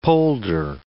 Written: polder

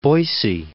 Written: Boise

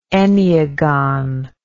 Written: enneagon

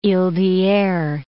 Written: iles d'Hyeres

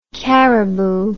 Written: Cariboo

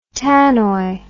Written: tannoy

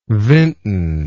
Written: vinton

